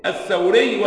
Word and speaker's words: الثوري: الثوري والتوزي. طبعا أسماء كما ترون النطق حتى صعب. الثوري الأول بفتح الثاء المثلثة وإسكان الواو وبالراء الإمام الثوري سفيان.